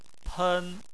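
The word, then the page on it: pen: tone 1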